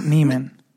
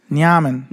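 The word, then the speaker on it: Nemanja: It's not Neiman. – Or Neiman? – Nyaman.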